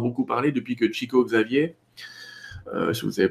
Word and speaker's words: Xavier: Mais euh, en tout cas, Chico Xavier, euh, Xavier, excuse-moi, je le prononce mal, Chico Xavier. – mon portugais est très mauvais.